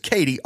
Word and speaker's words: KT: Here's a little bit more on Katie. This is from Wikipedia. Tunstall's first name is Kate, but she chooses to use her initials KT, pronounced Katie, instead, saying, quote, "Katie just makes me think of a buxom lass baking bread for her man working in the fields.